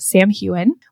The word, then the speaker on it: Samhain: So that is pronounced Samhain. We also have some mentions of word marks. And word is spelled W-Y-R-D, but it's pronounced W-O-R-D.